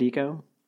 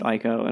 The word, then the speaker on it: Ico: So my next game is Eco, which is, do you pronounce it Eco? – I do. – I know some people pronounce it Ico, and I think those people are incorrect. Just demonstrably worse people.